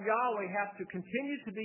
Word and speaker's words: Yahweh: Y-H-W-H, the tetragrammaton, which is pronounced sometimes as Yahweh, argued that that would be the correct pronunciation.